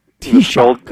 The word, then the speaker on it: Taoiseach: Taoiseach.